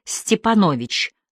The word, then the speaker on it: Степанович: Мы решили ограничиться инициалом Г и отчеством, которое в таком варианте походило на белорусскую фамилию Степанович с ударением на О.